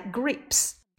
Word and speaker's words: grapes: 好，下一个葡萄 grapes，这是一个辅元辅义的结构，gr，哎，加上元音 a，再去加上辅音 p，再加上一个不发音的 e，连起来 grapes。